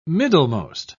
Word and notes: middlemost: British English

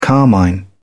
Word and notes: carmine: British English

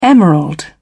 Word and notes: emerald: British English

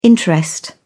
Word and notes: interest: British English

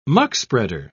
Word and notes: muckspreader: British English

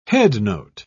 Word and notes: headnote: British English

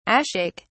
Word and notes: ashake: British English

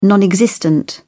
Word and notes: non-existent: British English

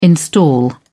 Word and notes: install: British English

